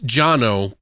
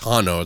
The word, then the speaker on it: Jano: And it's Jano, but it's Hano, it's pronounced, because... – Oh, okay. – It's a J, silent J.